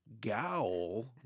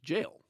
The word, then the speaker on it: gaol: spelled J-A-I-L. But in those days, they used the British spelling, which was G-A-O-L. And so people today look at it and go, gaol? What's that? What's a gaol? – Yeah, yeah, yeah. – But of course, it's pronounced jail, so...